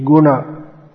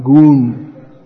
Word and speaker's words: Guna: So, these words should be Guna, Arjuna, not Goon.